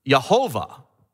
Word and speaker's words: Yahweh: Well, the one way to pronounce it is Yahweh. So it just depends on where you add the vowels. Yahweh, you've heard of Yahweh. And that might be how we should be pronouncing it. We just don't know for sure because we don't have the information. Or as the many people have and the King James authors have, it could be pronounced Yehovah. So now you hear Yehovah.